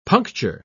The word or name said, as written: puncture